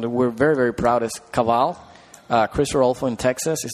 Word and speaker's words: Caval: Caval, C-A-V-A-L.